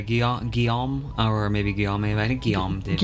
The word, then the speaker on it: Guillaume David: After that, you heard Children of the Omnissiah, that was by Guillaume David, I believe I'm pronouncing that correctly. – I think so, yeah. Guillaume David. – Guillaume? Yeah. Okay, thank you.